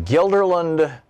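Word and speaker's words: Guilderland: Gilderland, New York. Am I saying that right? – You are, and good afternoon.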